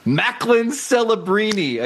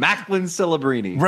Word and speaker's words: Macklin Celebrini: Macklin Celebrini. Again, remember, this is not Joe Smith. It's a memorable name, Macklin Celebrini.